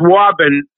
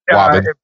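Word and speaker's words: Woburn: I hope I pronounced that properly. – You did. – Okay. – You did. It's Waben. – Waben.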